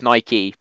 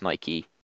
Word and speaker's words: Nike: No, it's Nike because of the Greek pronunciation. It's Nike. – Okay. – Which is why the shoe brand is pronounced Nike, but no one like. But we just say Nike in the UK because it sounds a bit weird. – Nike. – But it is actually pronounced Nike.